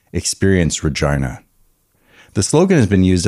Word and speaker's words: Regina: The gag, Regina rhymes with vagina.